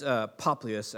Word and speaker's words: Publius: It says Publius. It's if you click on the little, I have this little cool program where you can see how to pronounce things, and it's Poplius, actually, is the Greek pronunciation of it.